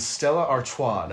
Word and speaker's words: Stella Artois: We're drinking Stella Artois.